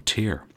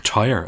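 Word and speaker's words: Tyr: The last time I spoke to you, we were discussing the 1989 album Headless Cross and the 1990 album Tear, or Tyre, as I actually learned today when rereading Joel McIver's book. It's pronounced like the word tyre, T-Y-R-E or T-I-R-E, although I think I'm going to continue to mispronounce it as Tear. I just feel more comfortable with the incorrect pronunciation.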